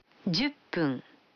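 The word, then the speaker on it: Дюппун (十分): Вы заметили, что когда фун добавляется к 10, дю, то произносится пун. – Послушайте и повторите каждую фразу. 5 минут. – Гофун. – 10 минут. – Дюппун.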